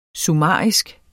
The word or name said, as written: summarisk